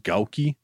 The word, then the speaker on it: Gouki: G-O-U-K-I. How's that pronounced? Gouki? – Gouki.